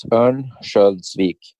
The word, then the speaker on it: Örnsköldsvik: How do you say it? Örnsköldsvik? – Yeah, close. Örnsköldsvik.